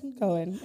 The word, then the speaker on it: Cohen: Cohen.